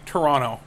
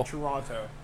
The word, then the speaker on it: Toronto: And see, Toronto. Say it right. – Toronto.